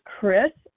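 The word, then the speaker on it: Kris: My name is Chris G. I spell it with a K, and I live in Tennessee.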